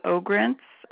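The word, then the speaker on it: Ogrinc: Mary Beth Ogrentz. Mary Beth is spelled M-E-R-I-B-E-T-H, and Ogrentz is O-G, like George, R-I-N, like Nancy, C, like cat.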